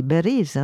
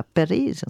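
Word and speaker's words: Beriz (باريس): Du point de vue phonétique, l'arabe n'a pas de P, comme je disais, et donc très souvent on prononce B pour dire Paris, on dit Beriz, hein, on ne dit pas Periz, on dit Beriz, des choses de ce genre-là.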